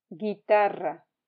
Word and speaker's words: guitarra: Guitarra, guitarra. Please remember not to pronounce the U in G-U-E and G-U-I.